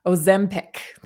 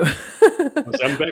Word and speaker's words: Ozempic: It's Ozpendemic or something like that. It's a weird name. I'm sorry, I'm completely butchering it. I should have written it down. But anyway, there's two companies that are making it. It's an injectable diabetic medication, actually, and young children are being permitted to use it. Yeah. It's being used. Ozempic. Thank you. Ozempic.